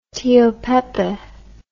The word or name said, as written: Tio Pepe